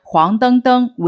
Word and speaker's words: 黄澄澄: 按照语言大师吕书香先生的解释，黄澄澄为 a b b 式结构，其中 b b 部分叠词要读成第一声。